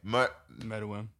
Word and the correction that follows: Meruem: Meruem. – Meruem. – King. Shout out to King. – The way they say it's Meruem. Yeah, you're right.